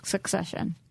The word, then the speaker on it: Succession: I have a hard time with my hard C's. – Ah. – Succession. – That's good. – That was right.